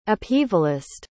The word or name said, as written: upheavalist